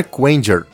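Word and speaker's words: Kuwanger: Como é que fala? Kuang? Kuang? – Boomer Quanger.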